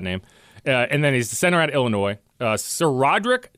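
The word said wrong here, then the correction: SaRodorick: Thompson, that's S.A. Roderick, not Sir Roderick.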